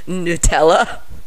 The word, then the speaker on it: Nutella: We've got brownies with Reese's Pieces, Nutella. Did I say that right? I never eat Nutella. – Yeah. I don't either. – Yeah? – You're right, yeah.